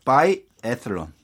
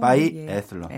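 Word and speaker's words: biathlon: 그리고 바이 에슬론. – 아, 아 이게 TH 발음이에요. – 네, 그렇습니다. 네. 바이 에슬러.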